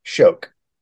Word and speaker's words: Schoch: So Bryce's last name is spelled Schoch, and it's pronounced Shoke.